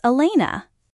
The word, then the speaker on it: Elena: My name is Elena.